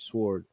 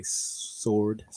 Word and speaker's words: sword: Alive and active, sharper than any double-edged sword. My wife makes fun of us. I say it slips out sometimes when I say sword. Anyways, that W is silent.